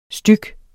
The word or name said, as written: styg